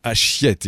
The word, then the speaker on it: Achiette: Nous, dans le Pas-de-Calais, nous avons Achiette, et on prononce bien le T, que ce soit Achiette le grand ou Achiette le petit.